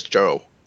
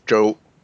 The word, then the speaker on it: Chou: So now I say my last name is Joe. Joe, it's so it's more like a J than a CH. – So Joe? – Yeah. You can say like J-O-E would be fine, would be closer than the prior.